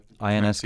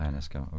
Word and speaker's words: Ionesco: Hoping I'm saying his name right, Eugène Ionesco, or Eugene Ionesco, or how do you guys usually say it? Ionesco. – Pronunciation is correct. – Ionesco, okay.